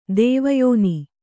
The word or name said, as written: ದೇವಯೋನಿ